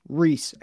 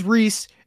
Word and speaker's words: Ries: Preston Reese, his name is spelled Rise, R-I-E-S. This one, I went on YouTube, I scoured YouTube, I found different podcasts, I found different interviews. It is pronounced Reese, and I am 100% confident in that.